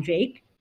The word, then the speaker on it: Jacques: Like, when I first read it, I was like, either it's going to be like some English person is going to be like Brian Jacques, but no, it's Brian Jacques. And then I was just like, but how do we pronounce it? So it's Brian Jake.